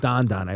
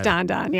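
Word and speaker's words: dan dan: It's actually pronounced don don. – It is don don, yeah.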